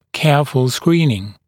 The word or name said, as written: careful screening